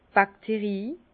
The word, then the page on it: d' Bakterie: Bas-Rhin; Herrlisheim